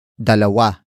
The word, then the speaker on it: dalawa: And that is dalawa. Dalawa. Dalawa. Dalawa is spelled as D-A-L-A-W-A.